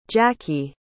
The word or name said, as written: jacqui